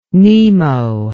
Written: Nemo

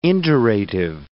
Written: indurative

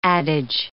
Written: adage

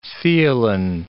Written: theelin

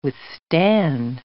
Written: withstand

